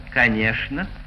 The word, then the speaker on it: конечно: В живом же языке эта группа «чн» перешла в «шн». И в живых словах произносится в Москве «шн». «Конечно», «скучно», «молочное», «булочное», «пустяшный».